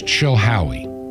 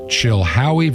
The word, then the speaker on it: Chilhowie: The city is Chilhowie. Chilhowie, Virginia. Chilhowie is spelled C-H-I-L-H-O-W-I-E.